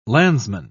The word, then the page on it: landsman: British English